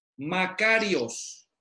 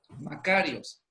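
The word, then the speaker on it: Makarios (μακάριος): Makarios. Makarios.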